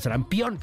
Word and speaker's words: sarampión: Regreso y platicamos rápidamente sobre el sarampeón sobre el sarampión, perdón, sarampión, ya lo pronuncie bien, sobre el sarampión, la otra vez, sarampión.